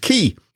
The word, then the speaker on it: Qiui: Key is spelt Q-I-U-I, but pronounced Key.